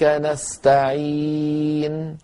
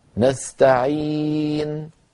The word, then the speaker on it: نستعين: وإياك نستعين، نستعين، مجرد ما سكنت النون مباشرة أضم الشفتين ضما ضما كما رأيتم كهيئتهما عند النطق بالضمة.